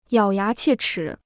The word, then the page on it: 咬牙切齿: yǎo yá qiè chǐ